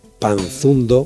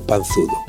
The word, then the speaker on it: panzudo: Así que decir repantingarse es como proferir panzanda por panzada, panzundo por panzudo o despanzurrar por despanzurrar. Recuerde que repantigarse viene de panza, nombre que no tiene más que una N y ya no dudará sobre su pronunciación correcta.